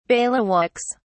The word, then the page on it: bailiwicks: British English